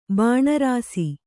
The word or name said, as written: ಬಾಣಾರಸಿ